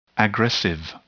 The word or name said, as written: aggressive